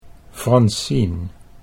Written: Francien

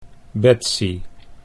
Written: Betsy

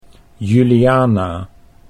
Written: Juliana

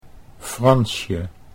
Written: Fransje

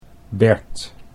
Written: Bert

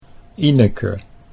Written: Ineke

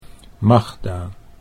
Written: Magda

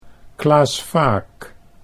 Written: Klaas Vaak

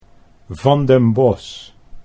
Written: Van den Bos

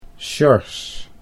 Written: Sjors

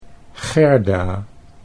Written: Gerda

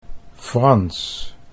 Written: Frans

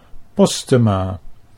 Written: Postema

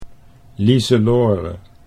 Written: Liselore

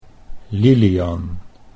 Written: Lilian

